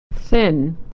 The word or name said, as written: thin